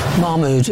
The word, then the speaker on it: Mahmoud: Mahmoud, is that right? Have I said that right? – Mahmoud's good enough, thank you.